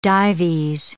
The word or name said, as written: Dives